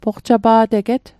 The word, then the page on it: poqtapa'teget: Mi'gmaq